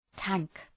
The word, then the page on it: tank: tæŋk